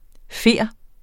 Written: fjer

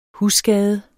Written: husskade

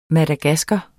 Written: madagasker

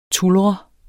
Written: tulre

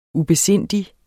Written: ubesindig

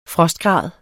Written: frostgrad

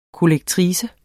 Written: kollektrice

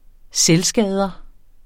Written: selvskader

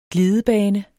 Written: glidebane

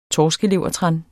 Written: torskelevertran

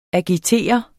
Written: agitere

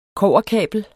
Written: kobberkabel

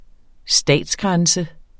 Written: statsgrænse

